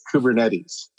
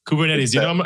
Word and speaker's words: Kubernetes: Let me help you with the pronunciation. It's Kubernetes. – Kubernetes.